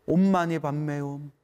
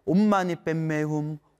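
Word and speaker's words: 옴마니반메훔: 옴마니 밤메옴, 옴마니 밤메옴, 옴마니 밤메옴. 티베트 발음으로는 옴마니 뱀메훔, 옴마니 뱀메훔, 옴마니 뱀메훔.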